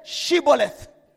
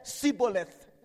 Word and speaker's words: Shibboleth: The Gileadites would say, can you say Shibboleth? – Now, people from Ephraim, they did not know how to pronounce that properly. They always say Siboleth. Are you guilty? They say Siboleth.